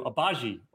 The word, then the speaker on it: Agbaji: So we're going to do Agbaji over 12.5 points.